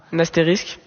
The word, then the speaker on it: astérisque: Vous hésitiez sur la prononciation, parce qu'il ne faut pas confondre évidemment avec le personnage de bande dessinée, mais vous l'avez dit, tu peux confirmer que c'est un astérisque.